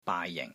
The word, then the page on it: BAAI JING: BAAI6 JING4